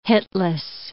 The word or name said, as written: hitless